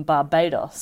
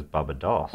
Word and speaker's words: Barbados: So he was a wealthy gentleman from Barbados. Sorry. Am I saying that right? – Well, I think the one you're meaning to say is Barbados. – Barbados. Thank you.